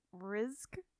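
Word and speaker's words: Rizq: There's no right way to pronounce this. He made it up. Risk. It's R-I-Z-Q.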